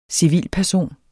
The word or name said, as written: CIVILT